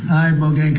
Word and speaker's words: Ibogaine: Is that pronounced relatively properly, would you say? – Yep. – I don't want to get it wrong. Ibogaine, because it's so important, and experienced an 80 to 90% reduction in symptoms of depression and anxiety within 1 month.